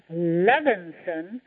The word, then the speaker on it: Levinson: My last name, Levinson, rhymes with heaven. It's Levin and then say son at the end.